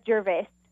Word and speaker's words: Gervais: Do you pronounce it Gervais or Gervais? – We say it Gervais. We kind of Americanized it.